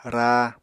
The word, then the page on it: raa: Apatani